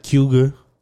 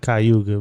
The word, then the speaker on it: Cayuga: It's spelled like Cayuga Lake, but they pronounce it kind of like Cuga, Cayuga. – Okay. – So you from Texas, so I know you pronounce it Cayuga, but the people there, they say it like Cuga or something like that. They kind of like put it all together.